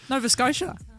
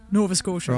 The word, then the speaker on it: Nova Scotia: Nova Scotia. Nova Scotia.